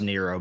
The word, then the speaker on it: Nyro: It's pronounced Nero, but it's like N-Y-R-O.